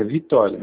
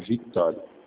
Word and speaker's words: Victório: Vitório Peixoto Júnior. – Ah, é Victório? – Tem um C antes. É Vitório, mas tem um C. Se escreve Vitório.